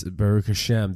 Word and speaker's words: Baruch Hashem: That's Baruch Hashem. That's how it's pronounced also, Rosh Hashanah.